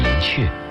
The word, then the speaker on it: 寅恪: 当年清华上下都叫他陈寅恪先生，然而在不少字典里没有雀这样的读音，有人请教他，为什么大家都叫你寅雀，你却不予纠正呢？